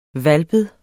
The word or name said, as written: hvalpet